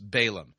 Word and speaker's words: Balaam: You know, I seem to recall, you familiar with the story of Balaam? Yeah, you may have heard it pronounced Balaam.